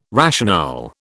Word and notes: rationale: British English

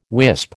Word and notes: wisp: American English

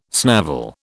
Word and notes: snavel: British English

